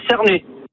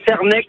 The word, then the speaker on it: Cernex: Alors moi j'habite à Cernay. – Voilà, je voulais savoir comment ça se prononçait. Merci. Voilà, au revoir. – Oui, ça se prononce. D'habitude, moi, je le prononçais Cernex.